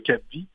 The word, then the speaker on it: capvie: Ça s'écrit Capviche, .org, mais on prononce Cap Vie en raison du cap sur la vie hein, de nos personnes en situation de handicap.